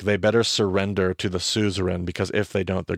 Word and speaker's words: suzerain: Because in the ancient world, we had what was called, I used to say Caesarian. But I found out today, in fact, that I've been pronouncing that wrong this entire time. It's actually suzerain, but we call them suzerain vassal covenants. And in the ancient world, there was two parties to a covenant, a suzerain and a vassal. – And just to clarify, that's spelled S-U-Z-E-R-A-I-N.